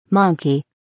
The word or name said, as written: monkey